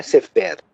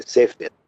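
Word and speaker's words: sefer (סֵפֶר): a sílaba é tônica, né? A palavra sefer quer dizer livro, então é, não é sefer, é sefer, né?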